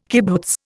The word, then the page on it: kibbutz: Indian